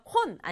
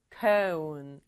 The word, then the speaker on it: cone: cone은요, o 다음에 e가 따라 나왔잖아요. o의 이름처럼 o 소리를 내야 돼요. o, o. 그래서 콘 아니고 cone. 아이스크림 콘 아니고 아이스크림 cone.